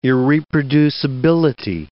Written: irreproducibility

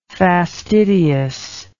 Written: fastidiousness

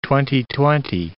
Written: twenty-twenty

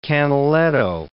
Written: Canaletto

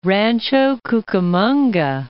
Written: Rancho Cucamonga

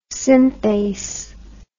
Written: synthase

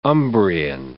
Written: Umbrian

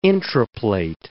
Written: intraplate

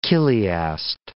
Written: chiliast

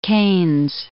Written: Keynes

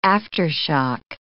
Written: aftershock

